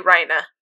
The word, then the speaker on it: Reiner: You'll meet a character named Reina, but it's spelled Reiner. But they always say Reina.